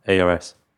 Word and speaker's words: Ars: ARS. – Ars. Don't know your Rs from your elbow.